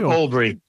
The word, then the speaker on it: Albury: Tell me about the Albury, how do you say that? Albury? – Albury.